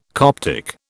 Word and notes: Coptic: British English

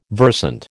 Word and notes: versant: American English

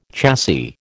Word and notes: chassis: American English

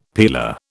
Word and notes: peeler: British English

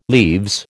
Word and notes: leaves: American English

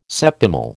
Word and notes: septimal: American English

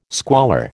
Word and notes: squalor: American English

